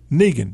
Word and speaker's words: Negan: Negan. Did I say his name wrong? – I don't think so.